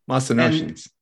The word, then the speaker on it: Mycenaeans: Chapter four, there's these people called them, Jeff, you might have to help me with the pronunciation, Mycenaeans. – Yeah, Mycenaeans.